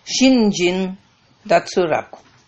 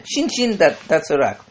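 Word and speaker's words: shinjin, datsuraku (身心): Só que o japonês não vai falar shin, shin, né? Shin, shin, datsuraku. Então vira shinjin, datsuraku. Esse primeiro shin aí é um ideograma que significa corpo. Corpo físico, tá? E o segundo, Shin, que aí passa a ser pronunciado como Jin, é o ideograma para coração-mente.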